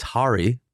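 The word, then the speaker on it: Harri: So I asked his agent today, and we are, in fact, butchering his name. All three of us have been butchering his name. But what do we know? We got bad advice. – Classic. – So first of all, it's Harry.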